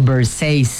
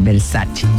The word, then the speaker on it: Versace: Por ejemplo, Versace, cuando la marca Versace es italiana, entonces tienes que pronunciar Versace.